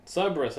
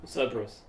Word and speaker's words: Cerberus: Cerberus, I kept mispronouncing it, I was like, Cerberus. Like a three-headed dog from the underworld.